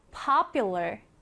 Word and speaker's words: popular: 자, 이 단어에서 강세부터 살펴보면 맨 앞에 이렇게 강세가 들어갑니다. 그래서 popular, popular 이라고 발음을 하고요.